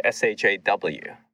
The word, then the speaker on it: Xiao: So everyone who knew me through I would call sophomore year of college would pronounce my first name like S H A W, like Shaw.